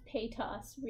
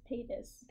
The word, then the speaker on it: Paytas: You pronounce Paytas weird. It's Paytas.